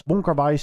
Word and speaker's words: Wong Kar-wai: Net als Wong Kar-wai 2046, zeg ik dat goed? – Ja.